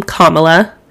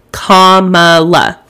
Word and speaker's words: Kamala: She sat down with Vice President Kamala, that's how you say her name, Kamala, not Kamala, I'm sick and tired of correcting people, Kamala, like a comma and then la, Kamala, Vice President Kamala Harris.